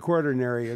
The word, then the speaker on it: Quaternary: I want to say quaternary, but it's not. – Quaternary. – Quaternary geologists. Quaternary. I got to get that right.